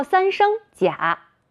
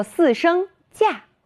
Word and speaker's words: 假: 当它表示不真实的意思时，念作三声假；当表示按照规定或经过批准暂时离开工作、学习场所的时间时，念作四声假。